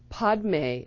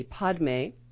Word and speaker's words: Padme: Padme is the way it's spelled. P-A-D-M-E. Padme is lotus. But the Tibetans pronounce it Padme. So either way is fine.